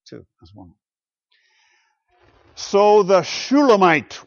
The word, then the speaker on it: Shulamite: That name, too, Shulamite, has the same Hebrew consonants as the name Solomon, with different vowels, however. So we pronounce it a little bit differently, but you can hear the resonance of Solomon with Shulamite, perhaps.